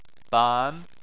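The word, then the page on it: ban: tone 2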